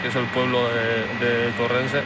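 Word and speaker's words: Torreense: O sea, Torrense, ¿no? Se dice así. – Torrense. Bueno, en Portugal, Tujiense, pero sí, Torrense.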